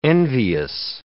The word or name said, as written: envious